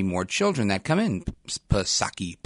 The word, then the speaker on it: Psaki: Speaking to reporters at the White House on Friday, Jen Psaki, she acknowledged, and you know her, Jen Pacircle Back Psaki, silent P.